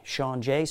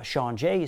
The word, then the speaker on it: Shaun Jay: Just type in Making Magic with S-H-A-U-N-J-A-Y, Sean Jay spelled that way, or you can just type in my name, Sean Jay is spelled the same way, and you'll find the show.